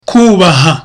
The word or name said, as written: kubaha